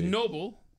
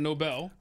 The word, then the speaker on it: Nobel: It was Noble instead of Nobel.